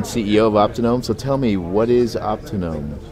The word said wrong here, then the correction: Autonomy: Sorry about that, but it's actually pronounced autonomy. – Oh, excuse me. I am learning here. – Get, so autonomy, but thank you. Go ahead, autonomy.